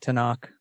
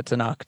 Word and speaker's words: Tanakh: Those are just vowels to get you to say the Tanakh, T and K-H, yeah. – Yeah, that's the phonetic pronunciation of the three letters.